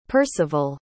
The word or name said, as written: Percival